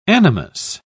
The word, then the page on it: animus: British English